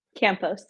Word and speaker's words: Campos: Campos, yeah.